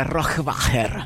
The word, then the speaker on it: Rohrwacher: E soprattutto, come si pronuncia Rohrwacher? – Penso proprio così. Sì.